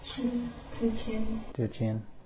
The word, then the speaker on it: Zhi Qian: The other one by Zhi Qian. Is that right? Can we have pronunciation correction on that? – Zhi Qian.